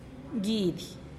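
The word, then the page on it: giidih: Teotitlán del Valle Zapotec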